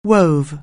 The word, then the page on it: weave: anglais